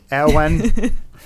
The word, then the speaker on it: Ewan: I, I'm curious because I like I always call him Ian McGregor, but it's Owen.